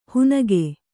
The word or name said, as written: ಹುನಗೆ